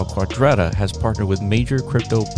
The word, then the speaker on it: Quadrata: I've heard Quadrata. – Yes, Quadrata. – Okay, Quadrata.